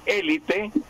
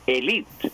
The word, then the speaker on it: élite: Es decir, que podemos decir élite, y en español podemos decir élite, o podemos decir élite, con acento grave, la élite. Lo que pasa es que élite nadie dice, nadie pronuncia así, pero las dos fueron aceptadas así, élite con acento grave o élite con acento esdrújulo.